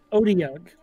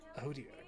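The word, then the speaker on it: otyugh: Odiug. – Odiug.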